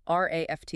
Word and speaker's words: RAFT: The program is called RAFT, and it's pronounced just like the word raft.